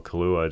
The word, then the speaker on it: Kaluuya: Daniel Kaluuya, to go with, am I saying his name right? – Yeah.